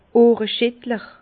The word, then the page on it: Ohreschittler: Bas Rhin; Herrlisheim